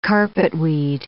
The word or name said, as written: carpetweed